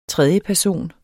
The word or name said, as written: tredjeperson